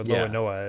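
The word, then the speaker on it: l'Olonnais: we also found out, thanks to the next SBS, that Zoro's other, last name, Roanoa, is inspired by the pirate Francois Lolonoi, or Loanoa, yeah, as the Japanese would pronounce it, which, yeah, I'm not familiar there with that pirate.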